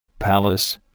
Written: palace

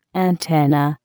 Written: antenna

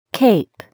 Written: cape

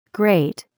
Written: grate

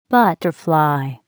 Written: butterfly

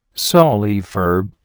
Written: sawleaf herb

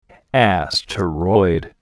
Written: asteroid

